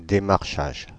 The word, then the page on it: démarchage: de.maʁ.ʃaʒ; Paris